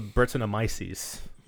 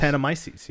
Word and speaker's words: Brettanomyces: I'm assuming that's the Brettanomyces. – Yeah. – In there, yeah. – That word that you've pronounced correctly all three times? – Brettanomyces, yeah.